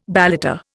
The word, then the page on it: balata: Indian